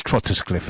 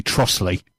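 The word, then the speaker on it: Trottiscliffe: The best one is Trotterscliff, isn't it? – Yeah. – Which is actually Trossley. – Yeah. – But it is spelt Trotterscliff. – I mean, it's Trossley is how it's pronounced, isn't it? – It's pronounced Trossley, yeah.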